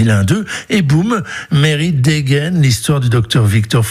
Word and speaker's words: Mary: Mary, on prononce comme ça, et son mari sont en goguette au bord du lac Léman.